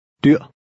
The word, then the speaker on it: dyr: Remember to pronounce the final R with a glottal stop. – Dyr.